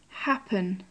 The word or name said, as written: happen